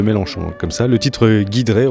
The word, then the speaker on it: Giedré: son, nom s'écrit Guiedré » mais ça se prononce Guidré.